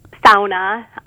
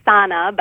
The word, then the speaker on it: sauna: So it does have some of these traditions, like sauna. People who aren't from around here would call it a sauna, but Upers are very adamant that it's pronounced the Finnish way. So you take a sauna and then you jump in the snow.